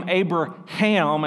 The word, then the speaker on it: Abraham: So God took Abram and made him Abraham, and there's emphasis on the H there, and I could go on a rabbit trail there, but I'm not.